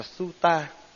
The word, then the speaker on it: sutta: Đây là tiếng Bắc Phạn, còn Nam Phạn á thì đọc là sutta.